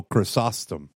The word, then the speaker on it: Chrysostom: Chrysostom? – Oh, Chrysostom.